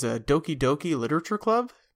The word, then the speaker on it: Doki Doki Literature Club: He writes in, the last game I played was Doki Doki Literature Club.